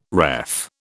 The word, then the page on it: ref: British English